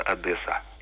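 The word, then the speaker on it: Одеса: По-украински произносится без удвоенного «с», просто Одесса.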